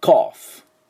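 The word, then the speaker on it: cough: クックと最後の F がポイントです。では私の後について発音どうぞ。コーフ、コーフ、はい、これが咳でした。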